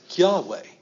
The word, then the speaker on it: Yahweh: That says Yahweh. In my English, I'm going to try and say it like a Hebrew would.